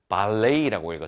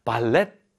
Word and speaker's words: ballet: 제가 어떻게 읽었죠? 발레이라고 읽었죠. 우리가 흔히 알고 있는 이 발레입니다. 발레. 저는 발레를 잘 못하지만요. 그래서 발렛 같은데요. 이게 발렛 같은데 발레이, T 발음이 무음입니다. 그래서 발레이라고 읽어야 된다는 점 알려드립니다. Then in 2008, 그리고 나서 2008년에는요.